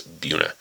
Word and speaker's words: Buena Vista: It looks like Buena Vista. The Colorado pronunciation is Buena.